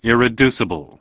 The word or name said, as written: Irreducibility